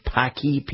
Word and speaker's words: Paqui: This is pronounced Paqui, P-A-Q-U-I.